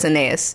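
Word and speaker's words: Anaïs: I'm pretty sure it's Anais. – It's got like an umlaut. Oh, it's Anais. It's got an umlaut over the I.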